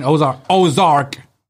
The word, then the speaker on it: Ozark: But no, I started watching Ozarks yesterday. – It's Ozark. – Ozarks, whatever. – And you're still saying it wrong.